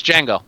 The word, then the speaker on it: Django: It's Django. – Oh, the D is silent. Fine. – Yeah. Django. Well, it's not silent. It's the D and J are together. It's J. Django.